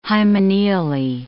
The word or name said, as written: hymeneally